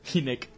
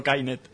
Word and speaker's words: Kinect: De la misma manera que toda la vida el jugador holandés Dirk Kuyt se pronunció Kuyt y no Kaut, pronunciad Kinect, no Kainet.